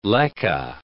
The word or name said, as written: leke